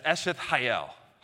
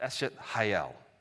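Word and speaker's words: Eshet Hayel (אשת חיל): And in Hebrew, it's pronounced with a little bit of my Southwestern Virginia flair as Eshet Hayel. Hayel, but it's got that sound. I'm not going to do that because it's awkward. But this is how it's pronounced. Eshet Hayel.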